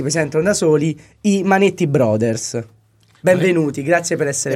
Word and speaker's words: Manetti Bros.: Quindi, no, Bros si legge Brothers.